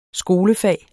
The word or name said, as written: skolefag